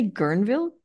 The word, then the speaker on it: Guerneville: I guess my first question is: Did I say Guerneville properly? That is how you say it, right? – You did.